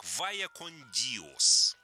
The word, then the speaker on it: Vaya con Dios: Понимаете, если название произносить на испанском языке с Иберийского полуострова, название звучит как «Vaya con Dios».